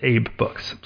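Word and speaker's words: AbeBooks: But the company themselves pronounce it Abe Books.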